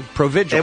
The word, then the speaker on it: Provigil: Provigil. – Provigil.